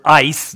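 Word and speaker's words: ice (εἰς): But this is the word ice, not I-C-E. Okay? Just that's how you pronounce the Greek letters, okay?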